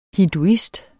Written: hinduist

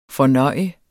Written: fornøjet

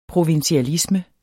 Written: provinsialisme

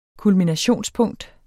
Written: kulminationspunkt

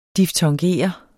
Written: diftongere

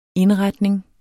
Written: indretning

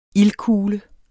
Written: ildkugle

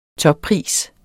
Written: toppris

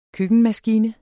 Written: køkkenmaskine

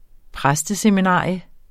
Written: præsteseminarium

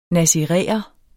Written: nasiræer

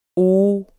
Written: oh

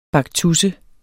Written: baktusse

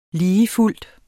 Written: ligefuldt

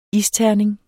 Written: isterning

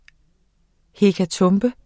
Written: hekatombe

